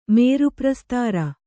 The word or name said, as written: ಮೇರುಪ್ರಸ್ತಾರ